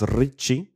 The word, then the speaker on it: Ricci: Im Fernsehen wurde er Ricky ausgesprochen. Ich würde ja sagen, es ist wahrscheinlich von der Wortherkunft hier ein italienischer Name, dann wäre es Ricci.